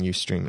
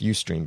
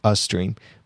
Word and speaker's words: Ustream: Maybe you didn't know there were other things other than Ustream. And it's pronounced Ustream, by the way. I heard someone pronounce it Ustream, but it's Ustream.